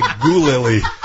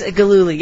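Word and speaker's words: Gillooly: And I couldn't pronounce Gululi. – Yeah, Jeff Gululi. – And it was on the teleprompter, and I kept calling it like Gulili.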